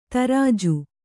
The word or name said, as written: ದರ್ಜೆ